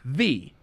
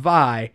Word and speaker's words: Vi: To which, and I had not read the books or anything like that, so I thought it was pronounced V. – Yeah. – And she was like, oh, actually it's pronounced Vi, but that's not a bad idea.